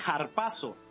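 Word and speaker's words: harpazo (ἁρπάζω): Si utilizamos la palabra robar, en el original la traducción sería, el enemigo vino para robar, se dice harpazo. Esa es la pronunciación.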